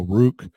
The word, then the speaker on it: Rook: So in the thing, it says it rhymes with juke. So Rook, Yeah.